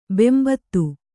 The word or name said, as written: ಬೆಂಬೂದಿ